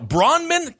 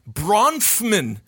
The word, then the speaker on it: Bronfman: It's literally Bronfman. And I'll tell you what it is. It's that F. They stick right in the middle. Bronman? Got it. Bronfman.